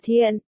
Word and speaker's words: Thiện: Dùng làm họ thì đọc là Thiện, không phải là Đan trong đơn giản, tôi là người Nam Xương, Giang Tây, nhà tôi ở quê không có tiếng lăm úi.